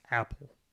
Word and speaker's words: Apol: But it's not spelled, it's spelled A-P-O-L, but it was pronounced Apple.